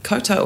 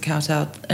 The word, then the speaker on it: kowtow: Koto or Kowtow. I say Koto, but most people would pronounce it Kowtow.